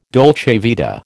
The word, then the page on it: dolce vita: American English